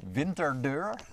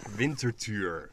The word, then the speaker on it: Winterthur: Winterdeur? – Winterthur.